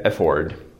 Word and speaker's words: afford: Tohle nahoře je afford. Přízvuk na druhé slabice dlouhé O. Tohle má přízvuk na první slabice, nevím jak naznačit krátké O. Tohle je effort.